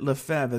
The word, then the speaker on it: Lefebvre: But he spells his name just like Alex Lefebvre, except between the E and the V, there's a B. But he pronounces it Lefebvre as well.